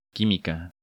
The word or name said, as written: Quimica